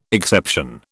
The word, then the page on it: exception: British English